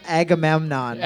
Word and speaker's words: Agamemnon: Agamemnon.